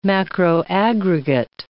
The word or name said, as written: macroaggregate